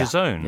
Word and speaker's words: DAZN: Oh, DAZN. Is that how you pronounce that? – Yeah. – DAZN? – Yeah. – Okay. – Doesn't make much sense when you look at it. – No, no, because it's DAZN. – Yeah, it's DAZN. – D-A-Z-N, yeah, DAZN.